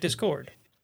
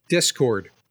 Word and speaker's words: Discord: I say it Discord. – Versus, how do you say it? Discord. – Yeah, he, he puts the emphasis on dis, where I put the emphasis on cord.